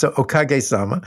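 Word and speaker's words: okagesama: is this idea of okajasama. Did I say that right? You're close. It's it's okage sama.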